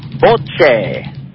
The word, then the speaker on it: bocce: But if you really want to hear the Italian pronunciation, it would be, drum roll please, bocce.